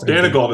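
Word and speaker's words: Daigneault: Because how do you pronounce his name, Mark? – That's right. – Danigold? – Danigold is how I've always said it.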